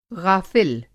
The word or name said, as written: ग़ाफ़िल